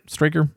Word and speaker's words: Straker: Straker.